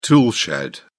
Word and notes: toolshed: British English

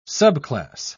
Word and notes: subclass: British English

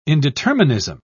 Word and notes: indeterminism: British English

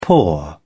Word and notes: paw: British English

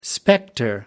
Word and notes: spectre: American English

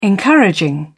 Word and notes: encouraging: British English

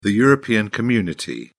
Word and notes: european community: British English

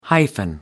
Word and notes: hyphen: American English

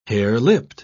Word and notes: harelipped: British English

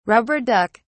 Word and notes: rubber duck: British English

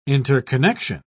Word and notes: interconnection: British English

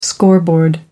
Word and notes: scoreboard: American English

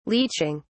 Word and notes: leeching: British English

